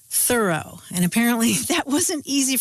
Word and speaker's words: Thoreau: writing about how their friend's name was pronounced Thoreau, with the accent on the first syllable.